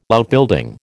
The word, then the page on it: Outbuilding: American English